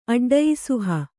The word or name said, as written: ಅಡ್ಡಯಿಸುಹ